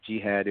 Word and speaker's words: Jihad: So it'll be Jihad Abdul-Mumit. That's Jihad.